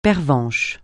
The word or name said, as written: pervenche